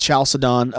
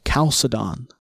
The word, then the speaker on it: Chalcedon: Well, let's get started off with the Council of Chalcedon. – Yes, Chalcedon, the Council of Chalcedon. There's no ch. You'll hear that Chalcedon.